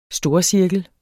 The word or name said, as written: storcirkel